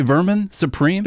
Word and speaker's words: Vermin Supreme: Can I say his name properly? Vermin Supreme? – That's it. That's it. – There it is. – It sounds wrong when it comes out, but that's right.